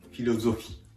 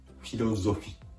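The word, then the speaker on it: philosophie: 続けて発音すると、フィロゾフィ、フィロゾフィです。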